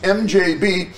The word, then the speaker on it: MDZHB: 24 hours a day, 7 days a week, since 1982, MDZHB, pronounced MJB, has been broadcasting a dull, monotonous tone followed by a second foghorn-like sound every second.